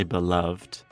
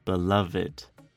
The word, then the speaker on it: beloved: Remember, this has three syllables, so don't say beloved. We would say beloved.